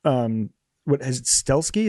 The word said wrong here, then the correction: Stahelski: is that how you pronounce his name, his last name? Stahelski. There it is. Stahelski. Yeah, Stahelski.